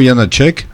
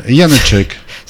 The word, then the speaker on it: Janáček: Et ce qui suivait, c'était le quatuor numéro 2 de Leo Janáček. – Ouais. – Là, je l'ai prononcé comme il faut. – C'est pas un Tchèque, Janáček. – Non. – Janáček. – Ça, d'accord. – Je crois. Hein, c'est comme ça qu'on dit peu ou prou.